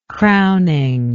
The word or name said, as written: crowning